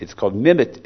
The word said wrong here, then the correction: mimetes: It's pronounced mimetes.